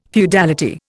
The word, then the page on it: feudality: Indian